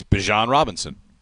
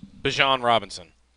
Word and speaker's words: Bijan Robinson: I think it's Dijon mustard, and he's Bijan Robinson. – Okay, Bijan Robinson.